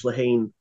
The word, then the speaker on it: Lehane: I am reading Moonlight Mile by Dennis Lehane, which that is the correct pronunciation, so you got to learn that in the next like 3 weeks. You got to trust me on this. You got to not call him Dennis Lehane.